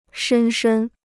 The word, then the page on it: 深深: shēn shēn